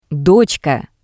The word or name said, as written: dotska